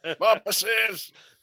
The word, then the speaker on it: Bumpuses: It's Bompuses? – Spell it. – I can't spell on a good day. – Bumpuses. That's pretty good.